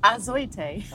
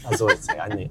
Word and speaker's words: Azoitei: Azoitei. – Azoitei.